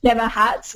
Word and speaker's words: Jemma Hatt: Jemma Hatt, which is spelled with a J-E-M-M-A and H-A-T-T.